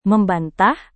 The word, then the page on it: membantah: Indonesian